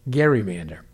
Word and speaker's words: gerrymander: He and his family insisted that they have always used the hard G, back through the generations. That's good enough for me, and apparently the rest of the town. Everybody there pronounces it gerrymander.